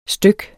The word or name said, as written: styk